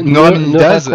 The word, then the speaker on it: neuraminidase: Par exemple, dans le cas du virus de la grippe, ce virus-là possède une neuraminase. C'est le N de H5N1, par exemple. Cette neuraminidase, elle lui sert à faire le... Attends, c'est dur à prononcer en plus. Neuraminidase, d'accord.